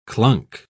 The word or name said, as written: clunk